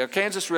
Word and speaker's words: Arkansas: Notice the proper pronunciation, the Arkansas River.